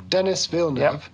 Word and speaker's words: Denis Villeneuve: So I listened to an interview with Denis Villeneuve. – Yeah. – I think I said that. Did I say that right? – Yeah, his name. That's right. – The director of the film, Denis Villeneuve, to say it in the English way.